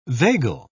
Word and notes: vagal: British English

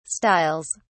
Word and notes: styles: British English

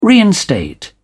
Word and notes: reinstate: British English

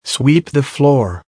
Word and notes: sweep the floor: American English

